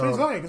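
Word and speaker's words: Ziyech: So basically, Zayek, I can't pronounce his name, Zeke. – Zeke? – I don't know how to say it. But it's Zayek. It's all good.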